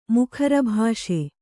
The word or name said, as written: ಮುಖರಭಾಷೆ